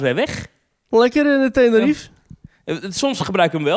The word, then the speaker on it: Tenerife: Dus ik, ben fan, Wikipedia, ik ben fan van Tenerife. – de Wikipedia van Tenerife staat... Tenerife wordt met vier lettergrepen uitgesproken... maar Nederlandstaligen laten meestal de laatste lettergreep weg.